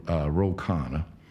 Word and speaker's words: Ro Khanna: No, there's one of the presidential candidates that I met during the campaign, Ro Khanna, who endorsed Oddity. Did I pronounce the name right? – Yeah.